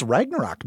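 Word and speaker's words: Ragnarök: Well, do you want to give us the correct Scandinavian pronunciation? We learned it last week. – No, I think we heard it in the movie. It's Ragnarok now.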